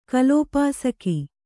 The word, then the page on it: ಕಲೋಪಾಸಕ: kalōpāsaka